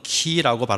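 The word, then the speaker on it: 키 (Χ): 그 단어를 따서, 물론 이 그 크리스토스라고 할 때 엑스는 헬라 발음으로는 키라고 발음을 합니다만은 줄여서 엑스마스라고 표현을 많이 합니다.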